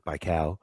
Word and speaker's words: Baikal: Now I know how to pronounce it, Baikal.